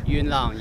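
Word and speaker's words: Yuen Long: This is a city, I'm probably going to mispronounce it, Yuenlong? – Yuenlong, yes.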